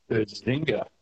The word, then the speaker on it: Bazinga: Rhymes with finger. Starts with B-A-Z. Buzz. – Buzz. – Bazinga. – You can do it. You can do it. – Bazinga. – Bazinga. Bazinga, correct.